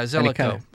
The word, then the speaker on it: Zelikow: Yeah, Zelikow. I said that right the first time.